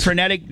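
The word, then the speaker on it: phrenic: How do you say it? Frenetic? Yeah, I think I said it. – No, perfect. – Frenetic nerve may have been damaged during surgery.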